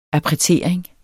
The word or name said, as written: appretering